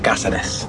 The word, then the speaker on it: Cáceres: We shot in Caceres. I should pronounce it, but in Cáceres. That's how it's pronounced.